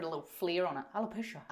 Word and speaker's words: alopecia: Something we were going to discuss today was, am I saying it correctly? Alopecia. – Yes.